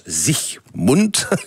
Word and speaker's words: Siegmund: Alors, l'homme fort qui a permis cette victoire, c'est Ulrich Siegmund. En allemand, ça se prononce Siegmund. Pas d'alga, je n'ai pas dit Sieg Heil, j'ai dit Siegmund.